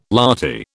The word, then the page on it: lathi: British English